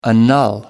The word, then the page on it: annul: American English